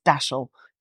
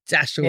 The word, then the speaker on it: Dashel: So you've got the speed of dash and the shell of the helmet, and decided to trademark that. But I agree, it's not easy to work out how to pronounce it. But yeah, do you know you're Cornish? It's Dashel.